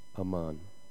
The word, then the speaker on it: amen: A Hebrew pronunciation of our English version, amen, is more like aman. Aman. Is that right? I think so. Aman, yep. You kind of have to have more of a lazy tongue.